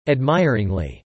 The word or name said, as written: admiringly